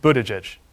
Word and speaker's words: Buttigieg: Sure, it's pronounced Buttigieg, but back home everybody just calls me Pete, so that's fine too.